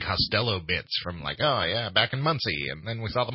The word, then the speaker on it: Muncie: Muncie. – Is that not how you say it? – No, that's how you say it.